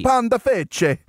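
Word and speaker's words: Pandafeche: I'm sure I pronounced that wrong, but panda, panda, fesh, f-e-c-h-e. – Panda fece. It's Italy. You got to say it, you know.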